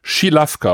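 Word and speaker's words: Žilavka: Im Prinzip alles, was der umgekehrte Akzent ist, wird dann Krsch, Bijeli Schilavka ausgesprochen. Schilavka.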